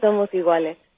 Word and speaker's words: Somos Iguales: It's called Somos Iguales.